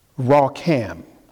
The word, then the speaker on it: racham: It's pronounced, it's spelled R-A-C-H-A-M, but it's pronounced Rakham.